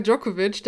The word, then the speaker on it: Djokovic: Also Novak Djokovic, der Name sagt wahrscheinlich allen was.